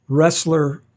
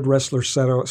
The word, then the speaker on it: Roessler: The third producer is Ressler Cellars. Brothers Richard and Roger Ressler founded Ressler Cellars in 2000, and that's spelled R-O-E-S-S-L-E-R.